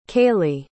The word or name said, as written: kayley